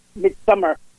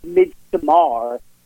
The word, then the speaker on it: Midsommar: And what I like about Midsummer, and it's actually pronounced Midsommar, if you want to be literal about it.